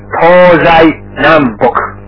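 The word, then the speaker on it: Tozai Nambok (東西南北): And we pronounce them Tozai Nambok.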